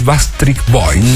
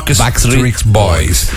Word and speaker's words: Backstreet Boys: Después de contar la anécdota, bien simpática, vamos a traer el tributo oficial en Latinoamérica de los Backstreet Boys. – No sé si lo dije bien. – Sí. – Lo dije bien. Backstreet, es que me cuesta mucho. – Los Backstreet Boys.